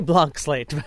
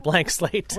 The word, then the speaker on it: Blanc Slate: I say blanc slate, but I have heard a lot of consumers come up and say, I'll try the blank slate.